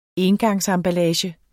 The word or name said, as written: engangsemballage